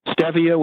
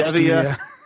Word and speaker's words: stevia: I use the three healthiest natural sweeteners, which are yacon syrup, stevia, which is probably the single most mispronounced word in the health food store. – I was going to ask so about stevia. – That's not stevia, right? Stevia, yacon syrup, and lohan, which the common name is monk fruit.